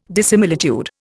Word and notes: dissimilitude: Indian

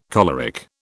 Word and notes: choleric: British English

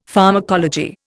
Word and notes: pharmacology: Indian

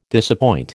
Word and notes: disappoint: American English

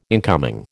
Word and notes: incoming: American English

